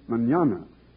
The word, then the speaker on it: mañana: It's the word mañana, spelled M-A-N-A-N-A, and pronounced mañana.